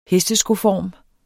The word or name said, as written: hesteskoform